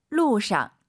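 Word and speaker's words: 路上: 이 샹을 선생님 사성으로 해서 원래 사성이잖아요. 루상 해도 괜찮죠? – 可以的. – 선생님은 사실 궁금해요. 선생님 발음이 너무 좋아서 우리 청취자분들과 저는 선생님을 따라가고 싶습니다. 아, 빙시 평소에 어떻게 하세요, 발음? – 칭성. – 아, 경성. 루상. 루상. 우리도 그럼 칭성 경성으로 하시죠.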